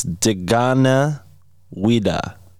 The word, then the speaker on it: Deganawidah: Of and I could totally be pronouncing this wrong, but it's Degana Wida, D E G A N A W I D A H.